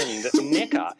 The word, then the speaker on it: NECA: And NECA is the correct pronunciation.